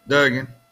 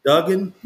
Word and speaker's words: Duggan: I mean, really, TCU's quarterback, is it Dugan or Duggan? – Duggan. – Max Duggan. – Duggan? – Max okay. Duggan, yeah.